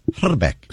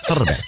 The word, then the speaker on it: Hrbek: I speak a little bit of Czech, and the actual correct pronunciation of his name is Herbeck. You got to roll the I. Herbeck.